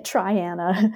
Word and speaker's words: Triana: It took me a while to learn how to say it, Triana, Alabama.